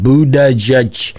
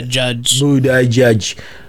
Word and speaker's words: Buttigieg: So, hey, listen, let me talk about Buttigieg, because I wanted to ask you, there was something that I saw about Buttigieg. well, I want to make sure I say his name correctly. Buttigieg. Because to me, it looked like Buttigieg.